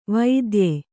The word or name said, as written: ವೈದ್ಯೆ